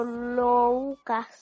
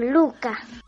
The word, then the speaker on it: Louca: Mi nombre es Luca y en realidad se escribe Louca porque está escrito en francés, que es mi idioma materno. En francés la O y la U juntitos se dicen U, como en español la letra U. Por eso mi nombre se escribe Louca, pero se pronuncia Luca.